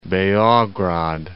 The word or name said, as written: Beograd